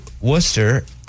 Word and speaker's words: Worcester: So Worcester is spelled like Worcester. – Mm-hmm. Yeah, like the sauce.